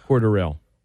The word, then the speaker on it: Cordarrelle: Cordell Patterson. – Cordell. – Where was? – Cordarrelle. – Cordell Patterson. – It's not even like Cordell.